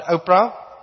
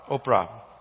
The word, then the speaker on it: Ophrah: Not Oprah, Ophrah.